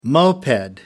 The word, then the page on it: moped: American English